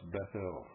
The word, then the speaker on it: Bethel: That should be pronounced Bethel, folks.